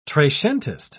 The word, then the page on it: trecentist: British English